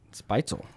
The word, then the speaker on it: Spätzle: And people like to say, though, because it's fun, is Spätzle. – Spätzle. – Spitzel. Oh, yeah. I like your pronunciation.